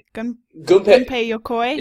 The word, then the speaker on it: Gunpei Yokoi: Gunpei Yokoi.